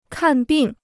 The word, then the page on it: 看病: kàn bìng